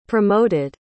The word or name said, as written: promoted